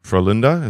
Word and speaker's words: Frölunda: Yeah, so that franchise, for people who don't know, Frölunda, is that correct pronunciation? – Yeah.